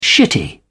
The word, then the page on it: shitty: British English